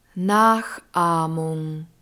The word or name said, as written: nachahmung